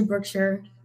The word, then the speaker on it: Brookshire: A lot of people say Brookshire. I say Brookshire.